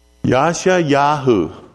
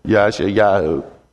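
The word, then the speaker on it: Yasha Yahu (ישעיהו): Isaiah's name, and this is something that I find surprising, because to pronounce Isaiah, it's not like Isaiah, like you might have heard somebody else say. That might be the English way to say it. But the Hebrew word is, and I looked this up and I said, Lord, are you sure about that? Yasha Yahu. I'm pronouncing it kind of phonetically here. Yasha Yahu.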